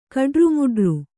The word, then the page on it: ಕಡ್ರುಮುಡ್ರು: Kannada